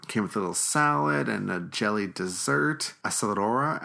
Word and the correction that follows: acerola: Acerora. – We can never say this right. Ace- Acerola. I can, yeah, I can't say it.